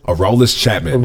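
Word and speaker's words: Aroldis Chapman: Araldus Chapman. – Araldus Chapman. I think that's how you say it. – Yeah.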